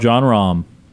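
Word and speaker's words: Jon Rahm: Yep, John Rahm.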